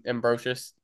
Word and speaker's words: Ambrosius: It looks how it's pronounced. – Oh, I guess. Yeah. Ambrosius.